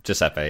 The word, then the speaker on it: Giuseppe: Giuseppe, yeah.